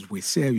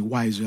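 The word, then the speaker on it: Weisser: So it says Weiser used to be the French pronunciation because it's W E I double S E R, like Weiss.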